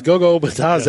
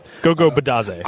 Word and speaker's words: Bitadze: Yes, Gogo Bitadze, yes.